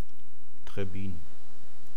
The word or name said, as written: Trebbin